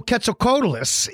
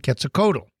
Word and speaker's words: Quetzalcoatl: So Quetzalcoatlus is named after an Aztec feathered serpent god named Quetzalcoatl. And here's why I say Quetzalcoatl. I usually don't pronounce the L. Quetzalcoatl. The reason why I don't say Quetzalcoatl, I say Quetzalcoatl.